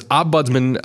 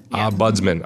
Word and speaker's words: ombudsman: I always feel like I want to pronounce that differently, but so it's ombudsman. – Ombudsman.